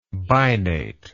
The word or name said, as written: binately